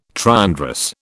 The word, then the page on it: triandrous: British English